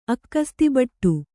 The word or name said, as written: ಅಕ್ಕಸ್ತಿಬಟ್ಟು